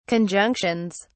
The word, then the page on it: conjunctions: British English